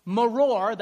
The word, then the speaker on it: maror: Now, the first part here I'll point to, maror, that's how you pronounce that.